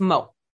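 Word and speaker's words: mort: It's mot. Mot.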